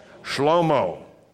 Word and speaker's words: Shlomo: How do you say Solomon in Hebrew? Shlomo. That's right.